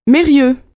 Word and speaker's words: Mérieux: Mérieux. – Comment ça s'écrit? – M-E-R-I-E-U-X. – Nom de jeune fille? – Perron. P-A-I-R-O-N.